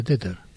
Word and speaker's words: Tether: que es Tether, si no me equivoco se pronuncia, sí, así como dije, Tether.